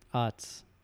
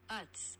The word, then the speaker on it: Utz: Utz. – Utz. – Okay, I'm the ass. – It's like shuts, but Utz.